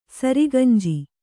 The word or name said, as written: ಸರಿಗಂಜಿ